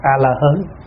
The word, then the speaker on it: A-la-hán: Cho nên thay vì A-ra-háp đọc là A-la-hán thì các cụ dạy là đọc A-la-hớn.